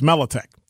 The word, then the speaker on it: Mladic: LT from the talking text line said, pronounced Mladic. Did I say Mladic? I like Mladic because I knew a guy with the same spelling named Mladic. Well, that's what we called him, but it's Mladic.